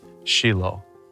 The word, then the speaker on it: Shiloh: I absolutely love being at this site here in Shiloh, or as the proper pronunciation is, Shiloh.